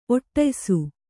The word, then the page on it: ಒಟ್ಟಯಿಸು: oṭṭayisu